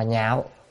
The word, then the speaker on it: nhạo: Chữ đảng này á có là chỉ, chữ này đọc là nhạo hoặc là lạc, nhưng mà đây là phần nhạo là ưa thích.